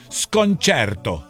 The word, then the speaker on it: sconcerto: Te digo algo, hace tiempo quería escribirte, pronuncias mal el desconcierto en italiano. Desconcierto sería sconcerto.